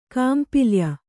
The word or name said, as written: ಕಾಂಪಿಲ್ಯ